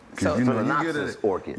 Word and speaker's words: Phalaenopsis: Phalaenopsis orchid. – Phalaenopsis orchid.